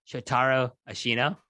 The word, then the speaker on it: Shotaro Ashino: By the way, it's Shotaro Ashino. You got to put the accents and the stress right on the certain syllables there.